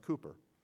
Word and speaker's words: Cowper: His name is spelled Cowper, pronounced Cooper.